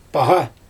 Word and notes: paGa: Xyzyl

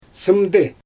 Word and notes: sɯmdɯ: Xyzyl

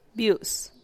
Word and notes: biu’s: Teotitlán del Valle Zapotec

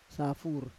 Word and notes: safur: Roon

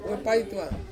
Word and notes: wepaituang: Ansus

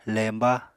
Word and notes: lemba: Apatani

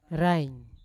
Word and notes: rain: Yawa

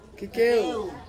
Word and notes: kikeu: Ansus

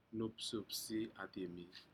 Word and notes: nupsupsi adimi: Aneityum

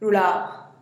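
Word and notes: rulaa: Teotitlán del Valle Zapotec

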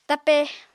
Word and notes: tape: Apatani

